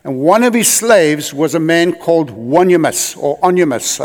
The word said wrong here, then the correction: Onesimus: I don't know how to pronounce his name. Onesimus, thank you.